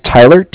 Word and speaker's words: Tyler: My name is Tyler, T-Y-L-E-R. – Okay. – And I'm calling from Bank of Cardiff. It's spelled C-A-R-D-I-F-F, as in Frank Frank.